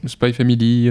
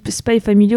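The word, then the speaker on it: Spy × Family: J'ai vu qu'après un roman, faudrait pas prononcer les X. Enfin. – Spice Family. – Spy Family, Hunter Hunter.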